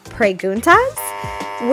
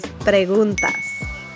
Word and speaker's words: preguntas: Now, for an American, that might sound like a perfectly great pronunciation of the word, but for native speakers, we can actually spot that pronunciation from a mile away because how you actually pronounce it is preguntas.